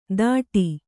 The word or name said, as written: ದಾಟಿ